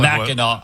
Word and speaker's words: Mackinac: Mackinac Island. – First of all, yeah. – It's spelled, why do they spell it Mackinac when it's Mackinac?